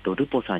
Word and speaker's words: REPOS: では改めてですね、ルポさんについてですけれども、表記がですね、 REPOS、ローマ字で REPOS、これをルポと読みます。